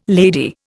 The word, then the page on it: lady: Indian